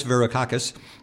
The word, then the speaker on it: Varoufakis: I was worried about pronouncing his name, and then I can't pronounce finance. Greek finance minister Yannis Varoufakis. There we go.